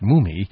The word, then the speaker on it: Mumy: I used to pronounce his name Mummy, but I've been told he, M-U-M-Y, it's pronounced Mummy.